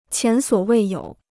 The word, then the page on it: 前所未有: qián suǒ wèi yǒu